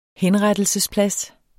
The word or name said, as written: HENRETTELSESPLADS